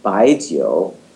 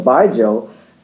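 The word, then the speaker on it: Baijiu (白酒): So when we say Baijiu, or the way the Chinese say it, Baijiu, that's actually a category of spirit, just like vodka or rum or tequila. Mm. So Baijiu, pronounced Baijiu in China, is in fact, you know, a huge category and the favorite, you know, national drink of China.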